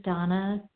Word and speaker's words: Dana: This is Donna B. My first name is spelled D-A-N-A.